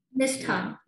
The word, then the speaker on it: Nishtha: Am I pronouncing your name correctly? – Nishtha, yeah.